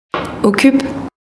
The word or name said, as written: Okup